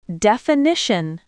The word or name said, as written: definition